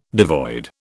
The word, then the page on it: devoid: British English